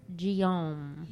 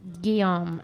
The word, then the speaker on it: Guillaume: Number one, the actor best known for playing Benson and Rafiki is Robert Guillaume. – Guillaume. – Guillaume. That's what I meant. – Guillaume. Yalami. – I wrote it phonetically. – Number two, didgeridoo is spelled D-I-D-G-E-R-I-D-O-O. And eucalyptus is spelled E-U-C-A-L-Y-P-T-U-S.